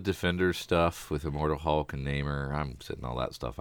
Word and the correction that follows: Namor: Did you call him Namor? – Namor. – Thank you. – Say it like you mean it. That's true. Like you know how to pronounce it.